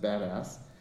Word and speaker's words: BDAS: It's abbreviated BDAS, and we pronounce it badass.